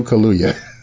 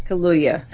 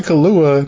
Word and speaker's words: Kaluuya: By the time you talk to him, We're going to get you to pronounce his name the right way. Daniel Kaluuya. – Kaluuya. Yeah. – I'm like, not Kahlua, not the alcoholic beverage.